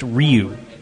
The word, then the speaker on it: Ryu: Zappy says it's pronounced Ryu. Just had to say it. – Ryu. – Ryu. Which he's right. It is. It's Ryu. I mean, when you look at like the Japanese pronunciation, how they pronounce their vowels, it's Ryu. He's absolutely correct.